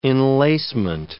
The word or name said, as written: enlacement